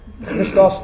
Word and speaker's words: Christos: Now, the Mashiach ended with the Christos starts with the. So can you say Christos? – Christos. – If you can't do it, just pronounce H-R. I, Christos, okay, Christos.